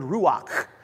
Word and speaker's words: ruach: It's the word ruach. You get some phlegm in the back of your throat, and you'll say it right.